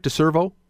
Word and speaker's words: deCervo: Uh, neuroscientist with deCervo. Did I pronounce it right? deCervo. Absolutely. – deCervo. Right. – Uh, D e c e r v o.